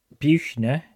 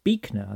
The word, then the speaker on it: Buechner: And also, interesting, fun fact, Frederick Buchner is actually pronounced Beekner, I think.